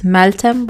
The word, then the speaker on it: Meltem: And for those who don't know, my account name on Instagram is Meltem Barcelona. It's spelled just as my name, M-E-L-T-E-M Barcelona.